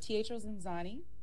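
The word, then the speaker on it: Teatro ZinZanni: right now you're in Chicago on a Teatro Ziazzini. Did I pronounce that right? – Close, but it's Teatro Zanzani, and it is like love, chaos, comedy, and dinner, and not just that.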